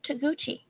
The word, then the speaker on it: Toguchi: Her name is Tina Toguchi. And her last name is spelled T-O-G-U-C-H-I, Tina Toguchi.